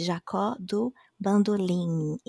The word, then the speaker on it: Bandolim: even though it ends in M, we pronounce it as an N, so it's Jacó do Bandolim, Tom Jobim, also it's like Tom Jobim, not Tom Jobim. It's not an M sound, it's an N sound.